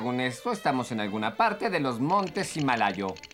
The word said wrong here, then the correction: Himalaya: ¡Se pronuncia Himalaya! ¡Himalaya! ¡Ay, eres un tonto de capirote! ¡Estamos en Asia, Himalaya!